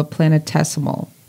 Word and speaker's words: planetesimal: It has been said that researchers think that 16 Psyche is the core of a planetesimal.